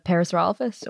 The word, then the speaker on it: Parasaurolophus: Parsifalophus. Am I saying that correctly? – Oh, the Parasaurolophus or? – There, nice. That's bingo.